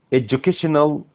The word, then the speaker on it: education: he followed اتبع an efficient efficient efficient اللي هي مؤثرة أو فعالة educational educational educational. احنا قلنا قبل كده، فاكرين education؟ الناس تقول لك education education، لا ما حدش بيقول كده. الدي دي بتتنطق ليتل جي، بيقول لك education education يعني اللي هو تعليم، educational اللي هو تعليمية، policy سياسة.